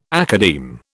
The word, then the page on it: academe: British English